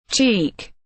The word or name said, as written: cheek